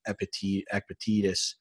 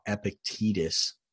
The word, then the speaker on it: Epictetus: So in speaking of that, I have another quote here I wanted to say from Epictetus. I said it wrong. Epictetus.